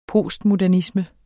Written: postmodernisme

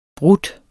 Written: brudt

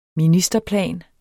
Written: ministerplan

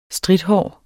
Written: strithåret

